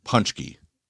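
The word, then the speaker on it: pączki: I think they pronounce them punchki, I believe. – But, yeah. – Yeah, anyway. So that's it. – Yeah, you never know it from the spelling here, though.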